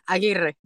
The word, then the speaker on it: Aguirre: Am I saying this right? – Yeah, Aguirre. But Aguirre. Yeah, it's perfect.